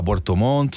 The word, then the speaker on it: Puerto Montt: No, y el calendario va a Puerto Montt, sobre todo, y bueno, para Colo-Colo también. – Pronuncie bien, usted es locutor. – ¿Dígame? – Puerto Montt. – Puerto Montt. Me faltó una T.